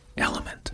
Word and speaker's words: LMNT: It's the letters L-M-N-T. Element.